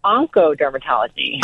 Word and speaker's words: oncodermatology: It's actually pronounced oncodermatology.